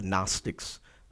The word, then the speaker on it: Gnostics: before the Gnostics spelled with a silent G, G-N-O-S-T-I-C-S.